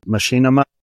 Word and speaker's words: Machinima: Machinima. – Yeah. – Ah, see, I'm close. See, rusty on the mic, folks. Don't. – That's hate. – Okay. – Machinima creator.